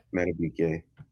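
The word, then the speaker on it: Madubuike: You got Justin Matabuke. Buke, I believe it's pronounced, right? – Matabuke.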